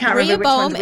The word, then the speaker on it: Rehoboam: Rehobabatham and Jehobabatham. And there's Rehoboam and Jeroboam. – Yes, that's it.